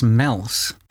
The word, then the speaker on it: Meols: A small town called, I think it's pronounced Meols, M-E-O-L-S.